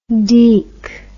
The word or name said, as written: deek